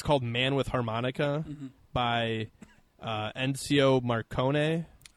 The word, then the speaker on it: Ennio Morricone: Ennio Morricone. It's cool. Yeah, he's up there in age. – That's how you say his name, yes.